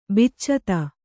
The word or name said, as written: ಬಿಚ್ಚತ